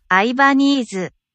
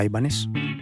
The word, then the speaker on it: Ibanez: Ibanese o Ibanez.